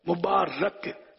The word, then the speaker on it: مبارک: پر زبر ہے مبارک صحیح لفظ ہے